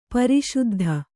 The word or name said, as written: ಪ್ರಸಿದ್ಧಿ